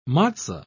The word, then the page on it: matzo: British English